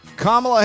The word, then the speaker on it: Kamala: Kamala Harris. Yes, that is how you say it too. Like, comma that you put in a sentence.